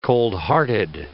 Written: coldhearted

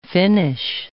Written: Finnish

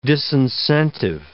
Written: disincentive

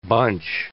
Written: bunch

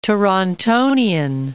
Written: Torontonian

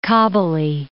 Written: Kabuli

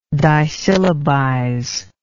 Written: disyllabize

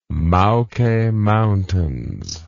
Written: Maoke Mountains

